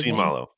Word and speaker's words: Siumalo: is it Siumalo? Is that how you say it? – Siumalo. Yep.